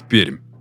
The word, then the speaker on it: Пермь: Город, находящийся на берегу Камы, город в Придуралии площадью 800 квадратных километрах и четвертый по масштабу населенный пункт нашей страны – город Пермь. Название города произносится с мягкой «р», хотя это и противоречит правилам русского языка. Благодаря этой особенности местные жители легко отличают приезжих.